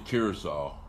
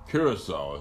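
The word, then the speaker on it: Curaçao: You know, and I believe that you pronounce it Curacao. Curacao, yeah. Caraco. Curacao is what it's pronounced.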